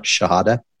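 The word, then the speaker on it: Shihadeh: S as in Sam, H-I-H-A-D as in David, E-H. Mark Shahada. That's the American way of saying it.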